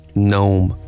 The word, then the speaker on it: gnome: So, what's the first silent letter of the word gnome? – G. – That would be correct.